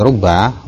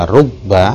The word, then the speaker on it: rubba: Kalau kita mendhamma huruf ra, maka huruf ba-nya boleh ditasdid, boleh tanpa tasdid, boleh juga sukun. Jadi boleh dibaca rubba, boleh ruba, boleh rub.